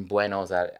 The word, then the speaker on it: Buenos Aires: Somehow the director decided to have the film entirely directed in Buenos Aires.